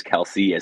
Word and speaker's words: Kelce: the proper pronunciation of his name is Kels, but Travis Kelce, as I continue to call him, just because old habits die hard, is on the COVID list.